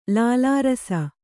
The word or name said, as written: ಲಾಲಾರಸ